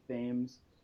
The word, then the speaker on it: Thames: It's spelled the same way as the river in England, but it's pronounced correctly, Thames, and that's led to some confusion.